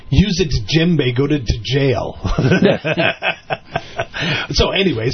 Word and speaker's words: djembe: Actually, one of my favorites was there was a bumper sticker that said, you know, djembe, you know that? D-J-E-M-B-I, I think is how you say it. Djembe, it's an African. But DJ, it's that sound, not a normal English sound.